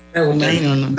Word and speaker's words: Reuters: No sé cómo lo pronuncian. Reuters. – Reuters. De Reuters, sí.